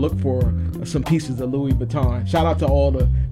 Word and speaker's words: Louis Vuitton: But Mid-Atlantic Jams, this is Louis Vuitton.